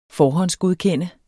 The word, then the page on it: forhåndsgodkende: ˈfɒːhʌnsgoðˌkεnˀə